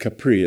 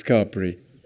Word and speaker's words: Capri: I know a lot of you think that should be pronounced Capri. It's not. It's Capri. You wear capris.